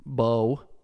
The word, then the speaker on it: Mbow: M-B-O-W, that's pronounced Bowe. I think it's like a little bit of M in there.